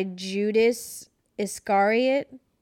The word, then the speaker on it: Judas Iscariot: Ecklund was believed to be possessed by Judas Iscariot.